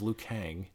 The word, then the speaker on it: Kang: L-I-U. Kane. The main. – You didn't spell it. – K-A-N-E. – Okay, his name's Liu Kang.